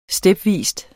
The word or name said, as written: stepvis